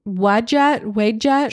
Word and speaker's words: Wadjet: Wadjet? – Sure. – W-A-D-J-E-T.